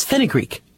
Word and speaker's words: fenugreek: It's called Fohngreek, F-O-E-N-G-R-E-E-K. – Oh, but one pronunciation is fenugreek.